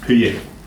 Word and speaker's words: Hugi: Huye. And it's spelled Hugi and pronounced Huye.